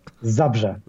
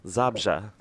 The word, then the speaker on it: Zabrze: Zabrze. – Zabrze.